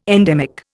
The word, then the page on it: endemic: Indian